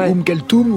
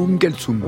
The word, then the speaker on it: Oum Kalsoum: Deux prononciations possibles, Oum Kalsoum ou Oum Kalsoum.